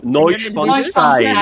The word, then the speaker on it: Neuschwanstein: pour faire les ériers c'est le château de Neuschwanstein. Alors, je suis désolée pour la, prononciation. – Neuschwanstein.